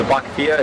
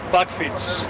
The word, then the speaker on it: Bakfiets: The Bakfia. It's like the box in front of the bike. With the little 20-inch wheel. I think they're the same people. What, how do you say it? – Bakfiets.